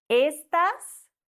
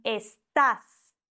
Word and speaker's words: estás: Und deshalb sagen wir nicht estás, sondern estás. Ganz arg wichtig. Wir sagen nicht esta, das bedeutet nämlich etwas anderes. Es gibt dieses Wort auch ohne Akzent und dann wird es anders betont und bedeutet etwas anderes.